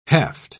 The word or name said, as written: heft